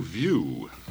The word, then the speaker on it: Viesu: It looks like it's spelled V-I-E-S-U, but he has in parenthesis view, so that must be the pronunciation. – Oh, probably. The S is silent.